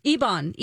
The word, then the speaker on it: Ebon: Ebon. E-B-O-N.